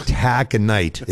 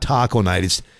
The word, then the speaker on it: Taconite: And every time that one came up, he'd call it Taco Night, and the program director would tell him it's Taconite. It's not Taco Night. And he would argue, no, it's like, dude, you're not from here. The town is not pronounced Taco Night. It's Taconite.